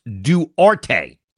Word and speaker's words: Duarte: It's pronounced Duarte. That's what it is.